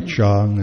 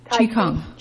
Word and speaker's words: Qigong: And then, you know, there's those stuff I can't pronounce, like Chong and Qigong. – Qigong and stuff like that, other guy did.